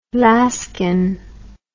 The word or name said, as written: Laskine